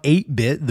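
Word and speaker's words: Atebit: I want you guys to also check out 8-Bit, the band that sponsored this episode. It's spelled, I think it's supposed to be like 8-Bit Graphics, but it's A-T-E-B-I-T.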